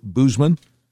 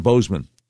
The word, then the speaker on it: Boozman: You might see his name and say, isn't that pronounced Bo- Boozman? No, it's John Bozeman. He is U.S. Senator from Arkansas, the one that's never on TV, the one you've never heard of if you're outside Arkansas.